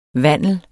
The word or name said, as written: vandel